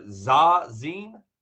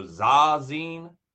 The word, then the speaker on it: Zazen: Sa or Zazen, Z A Z E N, meditation. So Zazen or Zazen, Zazen, I believe is how it's pronounced.